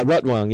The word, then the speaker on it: Rotwang: Rotwang. So, I don't know how you say his name. Rotwang? – Yeah, Rotwang.